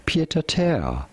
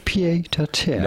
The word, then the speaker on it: pied-à-terre: In French, we would say pied-à-terre. A pied pied-à-terre. But it'd be fine in English to call it pied-à-terre. Pied-à-terre.